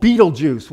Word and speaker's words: Betelgeuse: This star is called Betelgeuse, which I thought was an interesting name, but it's not spelled like J-U-I-C-E. The end of it is spelled G-E-U-S-E.